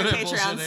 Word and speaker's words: Patreon: Is it pronounced Patreon or Patreon? – It's pronounced Patreon because of like patron.